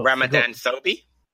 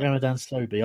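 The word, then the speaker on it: Ramadan Sobhi: But Ramadan Sobi. – Yeah, that's it, right. Ramadan Sobi.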